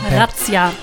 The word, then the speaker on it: Razzia: Before Razzia, or Razzia, how do you pronounce that? – Razzia. – Razzia.